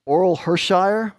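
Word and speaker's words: Orel Hershiser: Oral Hershire, remember? Did I say his name right? Yeah.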